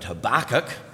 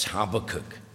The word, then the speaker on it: Habakkuk: They talk about Habakkuk, but we'll just use the Englified pronunciation. It's Habakkuk.